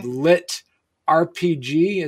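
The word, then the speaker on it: LitRPG: which is called Lit RPG.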